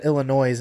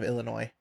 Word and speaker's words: Illinois: And then on top of that, he said Illinois instead of Illinois. – Can't you say Illinois? – The S is silent, buddy.